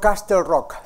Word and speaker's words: Castle Rock: Castle Rock, no Castle Rock. All right. Bueno, hay que aprender, ¿verdad? Entonces, la manera de acordarse cómo se pronuncia es olvídese de la T. Haga de cuenta que no existe.